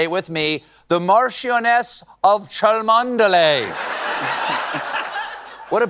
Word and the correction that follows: Cholmondeley: beautiful name that I'm being told right now that I pronounced incorrectly. It is, in fact, pronounced Chumley.